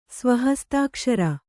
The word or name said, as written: ಸ್ವಹಸ್ತಾಕ್ಷರ